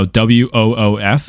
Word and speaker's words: woof: No, W O O F. Woof.